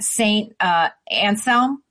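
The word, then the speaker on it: Saint Anselm: She was polling in the low 30s in some of the most recent, like, University of New Hampshire polls, and St. Anselm. Am I pronouncing that right? St. Yep. Anselm polling.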